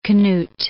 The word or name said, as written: Knut